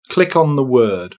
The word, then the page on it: click on the word: British English